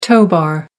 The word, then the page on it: tow bar: American English